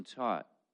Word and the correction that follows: tote: Tote, I'm sorry.